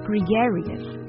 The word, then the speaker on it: gregarious: 我们分析一下这三个音节，gre，g-r-e，是吧？那注意呢，这个 e 的发音，gre，然后呢，get。 G-A 这个比较奇怪，就是这个 A 发的是什么音呢？是 red 那个 A 的音 gregarious。那 R-I-U-S 是 serious 后边那个一模一样的。